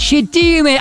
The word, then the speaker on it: щетиной: Данное существительное имеет фиксированное ударение на предпоследний слог, поэтому правильно говорить «щетины», а вы пошли от обратного, да?